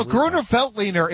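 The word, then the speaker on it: Grüner Veltliner: You can get three of those or one bottle of Grusswiener. I would go with the 2 for 1 Pizza every single day. And did I butcher the pronunciation of that Chardonnay, champagne, or whatever? Probably. – No, Gruner Veltliner.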